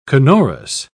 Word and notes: canorous: British English

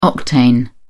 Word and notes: octane: British English

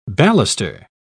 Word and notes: baluster: British English

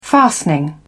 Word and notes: fastening: British English

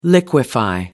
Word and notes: liquefy: American English

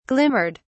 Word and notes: glimmered: British English

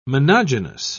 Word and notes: monogynous: British English

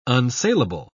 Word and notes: unsaleable: British English

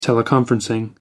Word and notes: teleconferencing: American English

